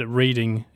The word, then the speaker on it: Reading: And I don't know if he was trolling us or not, but he wrote the word reading in here about 200 times.